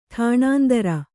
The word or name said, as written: ಟಾಣಾಂತರ